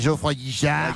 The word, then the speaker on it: Geoffroy Guichard: C'est Geoffroy Guichard.